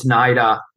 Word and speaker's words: NIDA: I hope that I pronounce it correctly, or it's NIDA. – It's NIDA.